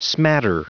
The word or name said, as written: smatter